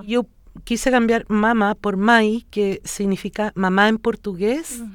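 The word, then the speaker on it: mãe: Porque si tú escribes pachamae, la gente dice mae, y no, es mai.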